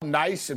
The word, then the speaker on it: Nice: It's spelled nice, and people are like, oh, it's nice. It's Nice, right? So it's Nice.